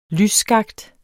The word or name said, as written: lysskakt